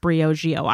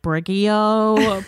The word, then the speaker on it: Briogeo: It took a while to understand that it's pronounced Briogeo. I was saying like Briogeo, Briogeo.